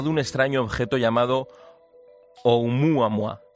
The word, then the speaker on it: Oumuamua: Pues muy bien, muy bien pronunciado en perfecto hawaiano. – Oumuamua.